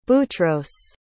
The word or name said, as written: boutros